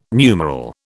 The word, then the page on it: numeral: British English